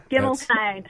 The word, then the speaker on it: Gimmelstein: Gimmelstein, three syllables.